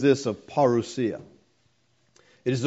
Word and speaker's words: parousia: Now, coming here is the Greek word parousia. I know you could say that however you want, but that's how it's pronounced in the Greek. Parousia is what most people say. Parousia is the Greek.